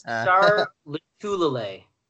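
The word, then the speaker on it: Lotulelei: Star Lotulele. I think that's how you pronounce it. – Lotulele. – Okay, you've got a better pronunciation than me.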